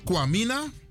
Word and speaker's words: Kwame: Dinsdag is Kwamina. Woensdag is Kwaku. Donderdag is Jou. Vrijdag is Koffie. Zaterdag is Kwami. En zondag is Kwasi. Even kijken. Uitspraak Kwami, Kwame. Dat klopt.